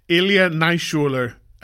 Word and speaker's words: Ilya Naishuller: Ilya Naishuller is the director.